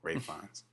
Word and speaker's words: Ralph Fiennes: Rafe? Is that how it's pronounced? Okay. Rafe Fiennes. Thank you. – Even though it's spelled Ralph.